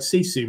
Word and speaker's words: Sisu: So I think probably just explain what the word Sisu means to begin with. So Sisu is if we're going to pronounce it correctly, is a Finnish word, which means to keep going, even when things get harder and harder.